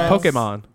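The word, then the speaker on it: Pokémon: How you say it. It's Pokemon. – It's how it's actually. – It's how it's pronounced. – So. Just gonna skip past it. Keep going. – Pokemon. – Pokemon. – Pokemon. Pokeymon. Pokemon. – Pokemon. – There's no Y in Pokemon.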